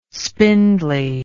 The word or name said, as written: spindly